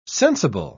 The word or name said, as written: sensible